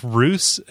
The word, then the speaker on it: Reus: His video game related question was, I got Reus. Is that how you pronounce it? – I think Roos. It's like Zeus, but with an R.